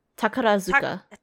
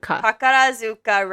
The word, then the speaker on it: Takarazuka: But yes, I think it's Tarakazu Revue. – Is the name of it? – Yeah, see, I just can't pronounce it. – I'm sorry. It's Takarazuka Revue. Takarazuka. – Takarazuka Revue.